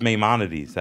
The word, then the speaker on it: Maimonides: I thought it was in Maimonides. It's Maimonides, that's the name.